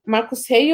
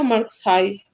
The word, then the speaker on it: Marcos Rey: Marcos Rei ou Marcos Rai? a pronúncia. Eu acho que é Marcos Rey.